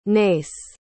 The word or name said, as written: nace